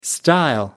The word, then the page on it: stile: American English